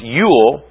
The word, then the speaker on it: Jule: Today's Yule log tradition comes to us from Scandinavia, where the pagan sex and fertility god, Jule, pronounced Yule, was honored in a 12-day celebration in December.